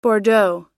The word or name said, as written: bordeaux